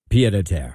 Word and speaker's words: pied-à-terre: Pied-à-terre.